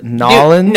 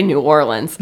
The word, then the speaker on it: New Orleans: Is it Nollins? – No, you say New Orleans. – New Orleans. – Okay, here's the fucked up part about New Orleans.